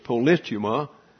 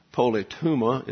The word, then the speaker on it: politeuma: The personal sense of destiny is related to the politeuma, that's the way it's accurately pronounced, but polytuma is the way we like to say it.